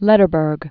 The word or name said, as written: Lederberg